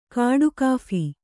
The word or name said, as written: ಕಾಡುಕಾಫಿ